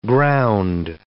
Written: ground

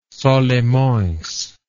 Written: Solimoes

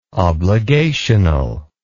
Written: obligational

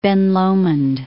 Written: Lomond, Ben

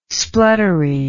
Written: spluttery

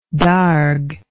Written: darg

